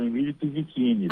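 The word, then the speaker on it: Emílio Pizzichini: Doutor Emílio, é assim mesmo que pronuncia seu nome? Boa tarde. – Oi, boa tarde. É assim mesmo, Emílio Pizzettini, tá? – Ai, que bom, doutor.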